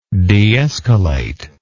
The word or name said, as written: deescalate